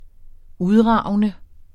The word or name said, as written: udragende